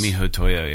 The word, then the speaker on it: miHoYo: It is not Tencent, but MiHoYo or whatever. MiHoYo or however you pronounce that. The developer is... They're Chinese. – MiHoYo, yeah.